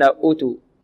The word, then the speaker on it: أوتوا: دلالة على نطقها وأنها منطوقة، ليست ككلمة أولئك التي كتبت فيها الواو ولم تنطق، بل هذه الواو في أوتو الواو مكتوبة ومنطوقة، فلا يجوز للقارئ ولا يصح من القارئ أن يقرأها بدون واو فيقول أوتو الذين أوتوا، وهذا بعيد حقيقة، بعيد يعني لا يقع فيه طلبة القراءة في هذا الخطأ، الخطأ في هذا بعيد.